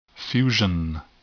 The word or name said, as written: fusion